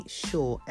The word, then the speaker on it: Shaw: That's Desiree, D-I-S-R-E-E, Shaw, S-H-A-W.